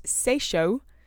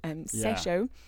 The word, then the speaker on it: Tsesho: I think it's pronounced Seisho. T-S-E-S-H-O in English. Seisho.